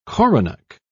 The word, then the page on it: coronach: British English